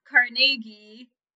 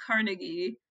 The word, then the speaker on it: Carnegie: He's from Scotland, and in Scotland it's pronounced Carnegie, but in the U.S., at least in northern states in the U.S., it's usually pronounced Carnegie. To appease potential Scottish listeners, other people from across the pond, I will say Carnegie.